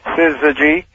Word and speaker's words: syzygy: Oh, it sounds like there's an R in there. – Well, it shouldn't. Syzygy.